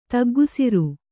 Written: ತಗ್ಗುಸಿರು

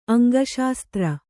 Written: ಅಂಗಶಾಸ್ತ್ರ

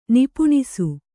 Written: ನಿಪುಣಿಸು

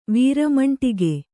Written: ವೀರಮಂಟಿಗೆ

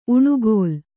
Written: ಉಣುಗೋಲ್

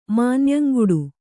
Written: ಮಾನ್ಯಂಗುಡು

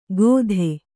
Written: ಕೊದೆ

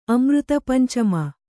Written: ಅಮೃತಪಂಚಮ

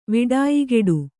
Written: ವಿಡಾಯಿಗೆಡು

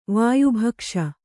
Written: ವಾಯುಭಕ್ಷ

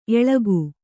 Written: ಏಳಕ್ಕಿ

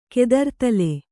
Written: ಕೆದರ್ದಲೆ

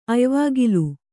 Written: ಅಯ್ವಾಗಿಲು

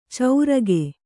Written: ಚೌರಗೆ

